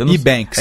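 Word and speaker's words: EBANX: sabe a pronúncia correta? E-Banks. – É E-Banks. E-Banks.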